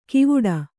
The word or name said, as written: ಕಿವುಡ